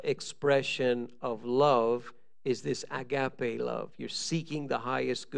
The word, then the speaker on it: agape: but one of them is agape, or agape, I guess you pronounce it.